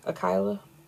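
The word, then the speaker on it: Akhalia: Akaya? Or Akylie? Akylia? – It's A-K-H-A-L-I-A. Akila. I think that's what I was going with.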